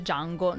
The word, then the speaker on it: Django: No, niente, si legge Django, non di Django, perché come dice lui nel film stesso, la D è muta.